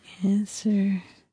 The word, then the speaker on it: answer: Or answer. – W. – W yeah.